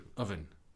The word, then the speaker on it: oven: I'm trying to say it with a U, oven. – Because that's how you say it. – That's how people say it, but it's wrong.